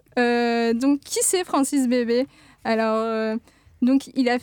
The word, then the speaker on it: Bebey: comment on le prononce comme, comme ça, Bébé.